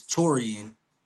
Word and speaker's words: Taurean: So a lot of people, they don't know how to pronounce my name, which is Taurean. And a lot of people were pronouncing Toron or Taurean, Taurean, or things of that nature.